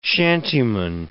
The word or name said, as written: shantyman